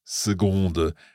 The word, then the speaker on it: seconde: Par seconde. It's spelled S-E-C-O-N-D-E, but it's pronounced S-E-G-O-N-D-E, seconde.